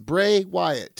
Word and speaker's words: Bray Wyatt: Two words, Bray Wyatt. Two syllables. It's not even three syllables.